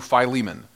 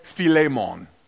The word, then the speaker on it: Philemon: No, technically it would be Philemon, and it would be a hard E because it is the eta. Is that right, Brandon? Is that an eta? And it's pronounced as a hard E, like an A, like the A. So it'd be Philemon, but nobody that's English pronounces it like that.